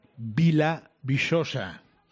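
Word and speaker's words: Vila Viçosa: Aquí en el Alentejo, muy cerquita de la raya, Vilavisosa. – Oh, qué ahí bien vamos. Lo dice. – qué bien pronuncia.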